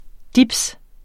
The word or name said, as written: dibs